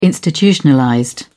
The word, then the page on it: institutionalized: British English